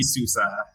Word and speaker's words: Souza: It's Souza, but I think you were pretty darn close to that. So it's all good. It's all good. – All right, perfect. So Tony Souza.